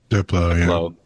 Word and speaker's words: Diplo: Diplo, whoever the fuck. – Diplo, yeah.